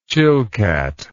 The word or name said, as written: Chilcat